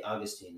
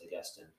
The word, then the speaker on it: Augustine: I say Augustine. It's also generally pronounced Augustine.